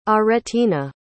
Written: aretina

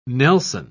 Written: nelson

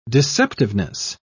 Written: deceptiveness

